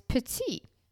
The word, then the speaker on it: petit: It has a funny spelling, P-E-T-I-T, and then grain, which if you have a French-Canadian background like me, that is petit, but it is pronounced petit.